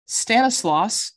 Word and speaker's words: Stanislaus: Stanislaus. Did I say that correctly? – You did. Good job.